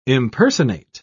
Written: impersonate

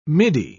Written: middy

